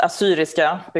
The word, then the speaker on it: Assyriska: I mean, so it's pronounced Assyriska because an Assyrian person is Assyrisk in Swedish.